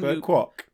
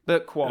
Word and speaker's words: Burt Kwouk: So is uh, Burt Kwouk, is that his, how you pronounce his surname? – Burt Kwouk. Burt Kwouk.